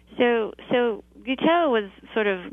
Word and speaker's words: Guiteau: Is it Guiteau? – Guiteau.